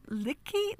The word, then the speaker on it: Liche: It's Lich, apparently. – Okay. – The English pronunciation. Carl Lich.